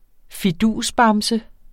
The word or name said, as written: fidusbamse